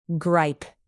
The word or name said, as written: gripe